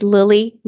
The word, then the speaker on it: Lily: My name is Lily Marsden, L-I-L-Y. M-A-R-S-D-E-N.